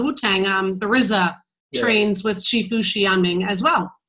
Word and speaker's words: Shifu: Shifu.